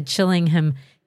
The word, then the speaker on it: Chillingham: We're going to dive into Chillingham Cat. Did I say that right? Chillingham? – Chillingham, yeah, that's right.